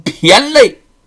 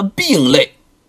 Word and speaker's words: 骈肋: 这孩子这身上的骨骼跟一般人不一样啊，长得是虬筋骈肋。有人呐不懂那个字儿，把那个字念白了，念成了病肋。其实那个字念骈肋。